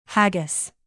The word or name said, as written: haggis